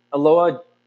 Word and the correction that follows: Enola: Holmes. Ilona? No. – Enola. – Enola. I knew I was saying it wrong.